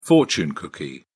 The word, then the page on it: fortune cookie: British English